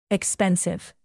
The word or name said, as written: expensive